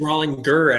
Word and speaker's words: Rollinger: So is it Rollinger? Is that correct? – It's Rollinger, actually.